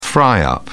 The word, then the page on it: fry up: British English